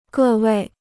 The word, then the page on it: 各位: gè wèi